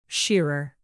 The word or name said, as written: shearer